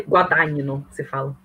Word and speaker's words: Guadagnino: GN é Nhe, então é Guadagnino, você fala.